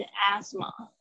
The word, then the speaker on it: asthma: 你念的比较像是英式发音，啊，美式的也稍微注意一下，是 asthma，它是 s 发成 z 的声音。